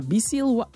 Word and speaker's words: Biesiel: Is het Biesiel of is het Bijsiel? Ja, Biesiel, Watsam.